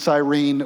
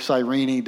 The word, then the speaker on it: Cyrene: But there's these guys from Cyprus and Cyrene, or Cyrene, depending on how you like to pronounce it, right?